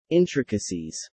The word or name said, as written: intricacies